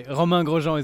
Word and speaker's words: Grosjean: I'm here with Kevin Magnussen and Romain Grosjean. Did I say that right? Is that close? – It was the closest it can be. Romain Grosjean is the perfect one, but it's very, very tough.